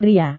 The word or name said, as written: pria